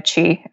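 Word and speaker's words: Qi: It's at Christina Chi, which is spelled Q-I.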